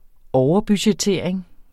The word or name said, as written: overbudgettering